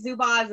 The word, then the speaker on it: Zubaz: And also want to hear, I want to hear about Ed's love for Zumbas, because I know that's like infamous. – There's no M in it.